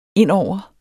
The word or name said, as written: indover